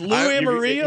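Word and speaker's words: Lou Anarumo: How do you pronounce that, guys? Lou Amarino. – You got it.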